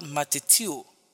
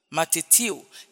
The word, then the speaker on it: matheteuo: That word, that phrase, make disciples, is from the Greek word matetio. Matetio is spelled M-A-T-H-E-T-E-U-O. Matetio.